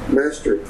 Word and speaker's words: Maastricht: Maastricht Treaty.